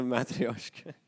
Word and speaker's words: matryoshka: The matryoshka. – I said it right this time. – Yeah, you did. You did get it right.